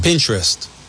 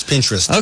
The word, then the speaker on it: Pinterest: Pinterest. – Right. – Yes. – Yeah. – I wanted to mispronounce it, and I was told last night, you take interest, and you put a P, it's Pinterest.